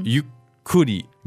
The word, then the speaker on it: ゆっくり: So as you can see here, there is a small tsu between the you and the ku. So that doesn't make it ゆっくり. It makes it, how do we pronounce that correctly? – ゆっくり。ゆっくり。 – So there's a small little stop right there between the you and the ku. It's ゆっくり means slowly.